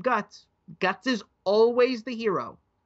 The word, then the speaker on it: Guts: Guts. G-U-T-S.